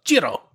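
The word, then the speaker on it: Cirò: And just for a quick pronunciation lesson, the wine is spelled C-I-R-O, and the O has an accent on it, which makes it a little bit confusing.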